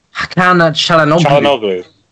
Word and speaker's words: Hakan Çalhanoğlu: Hakan Çalhanoğlu.